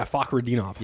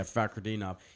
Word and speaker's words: Fakhretdinov: I can't say his name. – Yeah, Fakhretdinov. – Yeah, Fakhretdinov.